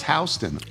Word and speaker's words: Houston: Oh, pronounced Houston. I'm sorry. – Oh, it's like the street in New York, right? – Right.